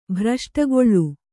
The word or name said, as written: ಭ್ರಷ್ಟಗೊಳ್ಳು